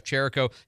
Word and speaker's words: Czerewko: And inbound, we have Jeff Cherico. His name is hard to say because it's spelled, you know, C-Z-E-R-E-W-K-O.